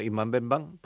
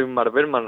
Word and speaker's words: Ingmar Bergman: Si lo queremos pronunciar bien, si decimos Imman Benban, pues ya está, pero lo queremos decir bien, es complicado. Los nombres suecos son así. – Este es más o menos sencillito, Ingmar Bergman, relacionados con todos los directores que he dicho de la película, o Ruben Östlund, por ejemplo, bueno.